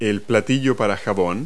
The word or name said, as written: soapdish